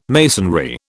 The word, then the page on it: masonry: British English